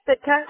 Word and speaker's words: Sitka: And am I pronouncing that correctly? Is it Sitka? – Sitka. Yeah.